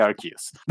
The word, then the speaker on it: Arceus: Y va a ser un problema aún más grande con el nuevo Pokémon Legends Arceus, porque al parecer no se dice Arceus, se dice Arceus.